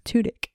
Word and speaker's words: Tudyk: Tudyk.